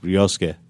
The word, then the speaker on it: Ryosuke: It's pronounced Ryosuke. – No, I'm not Japanese. – I know, I'm just saying it's pronounced that way.